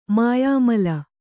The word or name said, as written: ಮಾಯಾಮಲ